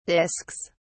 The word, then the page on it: discs: British English